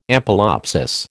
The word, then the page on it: ampelopsis: American English